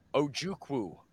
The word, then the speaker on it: Ojukwu: I'll let you pronounce John Ojukwu, O-J-U-K-W-U.